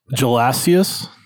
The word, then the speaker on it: Gelasius: Gelasius the first. Is it with a G or a J? A G. Oh, Gelasius.